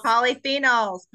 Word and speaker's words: polyphenols: Polyphenols.